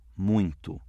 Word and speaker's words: muito: O ditongo nasal, o i, aparece apenas na palavra muito.